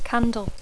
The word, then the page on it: candle: English